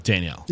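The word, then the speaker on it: Daniel: You go to Restaurant Danielle. – Yeah. – Am I saying that right? – Yeah, Restaurant Danielle.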